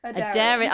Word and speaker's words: Aderyn: So what have you got coming up in the near future with Aderyn? – Aderyn. – Aderyn. I knew I'd said it wrong. That's why I said it like a robot.